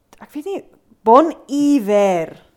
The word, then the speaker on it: Bon Iver: The name bon iver derives from the French phrase, bon hiver, French pronunciation. Ek kan nie fonetika lees. – Nee, maar daaronder staan hy in lettertjies. – Good winter. Taken from a greeting on the northern exposure, it is pronounced bon iver.